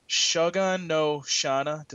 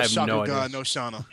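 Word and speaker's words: Shakugan no Shana: Shogun no Shana. Did I say that right? – No, Shogun no Shana.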